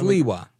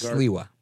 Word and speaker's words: Sliwa: Sliwa. Yes, Sliwa.